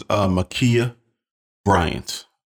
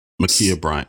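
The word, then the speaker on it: Ma'Khia Bryant: Her name was Makia Bryant, Makia Bryant, and she was 16 years old.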